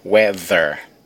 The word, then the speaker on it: weather: O título é weather. Olha a pronúncia. Weather. O TH sound.